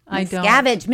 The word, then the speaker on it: Miscavige: Miscavige.